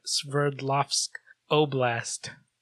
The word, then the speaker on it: Sverdlovsk Oblast: So that's a mountain in the northern part of the Soviet Sverdlovsk Oblast.